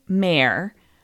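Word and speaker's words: Maher: Another person who had a new-to-them author they wanted to recommend said Kathleen Mayer. I hope I'm pronouncing that correctly. There's different ways of pronouncing it, but it's M-A-H-E-R, Kathleen Mayer.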